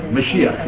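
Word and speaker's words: Mashiach (משיח): Mashiach, if you want the literal pronunciation.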